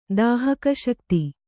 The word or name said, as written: ದಾಹಿಕಾಶಕ್ತಿ